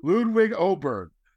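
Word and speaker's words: Åberg: And yes, that's his name now. Ludwig Oberg. We've all been pronouncing it wrong for years. So Ludwig Oberg.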